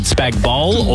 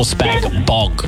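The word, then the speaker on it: spag bol: Is it Spag Bowl or Spag Bog? – Spag Bowl, the G's silent.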